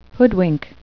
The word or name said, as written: hoodwink